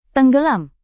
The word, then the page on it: tenggelam: indonesian